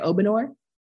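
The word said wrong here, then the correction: Obanor: Oh, Banner. There it is. Okay. – Obinor.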